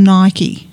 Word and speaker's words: Nike: It's a new release and it's a biographical sports drama film and it's based on Nike. Some say Nike, but in this film they actually pronounce it Nike, which I was quite happy about because I'm always being corrected by my kids.